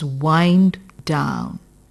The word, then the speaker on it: wind: WIND is wind is a noun and WIND here is used as a verb. So, the pronunciation is wind down.